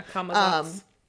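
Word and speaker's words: Camazotz: They were trying to, you know, get away from Kamazats, is that how you say it? – Yeah, Kamazats.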